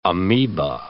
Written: amoeba

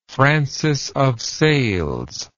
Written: francis of sales

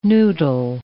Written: noodle

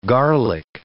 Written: garlic

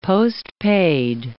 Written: postpaid

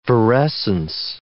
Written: virescence

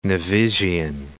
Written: Nevisian

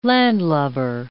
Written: landlubber

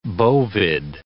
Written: bovid

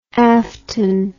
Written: afton

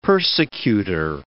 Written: persecutor

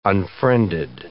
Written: unfriended

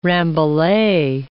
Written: rambouillet

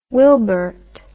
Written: wilbert

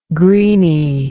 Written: greenie

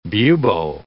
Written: bubo

